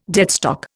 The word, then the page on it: deadstock: Indian